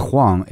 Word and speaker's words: Huang: And last name is Huang, H-U-A-N-G.